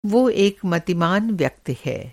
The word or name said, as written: मतिमान